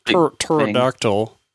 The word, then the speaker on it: pterodactyl: To not give too many specifics, by a pterodactyl, the P is silent.